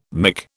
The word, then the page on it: mick: British English